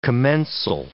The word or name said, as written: commensal